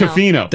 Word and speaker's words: Tofino: Then we went to Tofino, and they're like, stop hitting toe hard. Tofino. It's Tofino. – That's you in a nutshell. Someone pointed out Pearl Jam. – Yeah, that's true. – Pearl Jam? – Tofino.